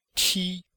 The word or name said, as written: ti1